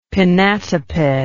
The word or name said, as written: pinnatiped